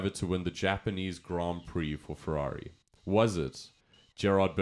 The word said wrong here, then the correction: Gerhard: Berger? – Gerhard. – Huh? – Gerhard Berger, buddy.